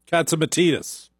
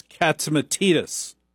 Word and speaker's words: Katsimatidis: Now, I work for a very unusual company in that it's owned by John and Margo Katsimatidis, pronounced Katsimatidis, as Margo taught me in no uncertain terms. It's Katsimatidis. She was very clear.